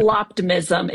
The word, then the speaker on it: Apocalyptimism: Hey, you recently wrote a piece called Reflecting on the AI Doc, or How I Didn't Change My Mind About Apocalyptimism. I totally butchered that word. I'll let you give that out to it. Apocalyptimism is how they pronounced it in the movie.